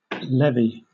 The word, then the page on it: levee: British English